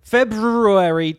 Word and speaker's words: February: February, is that how you pronounce it? – Yeah. – February 23rd, 7 p.m.